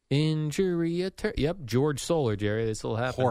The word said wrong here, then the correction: Jorge Soler: Jorge Soler.